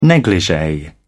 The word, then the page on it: negligee: British English